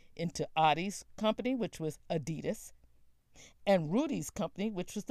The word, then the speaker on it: Adidas: And by the way, the correct pronunciation for Adidas is Adidas.